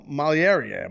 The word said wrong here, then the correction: Malignaggi: How do you say? I always freaking up his name. – Malignaggi.